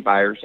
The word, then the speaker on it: Byers: It's Kimberly Byers, and it's Kimberly with a couple of Y's.